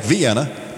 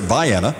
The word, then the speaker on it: Vienna: It's spelled like Vienna, but it's pronounced here Vienna.